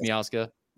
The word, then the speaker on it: Miazga: I assume it's Miazga. – Yes, that's correct.